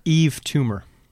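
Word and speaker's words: Yves Tumor: Eve Toomer.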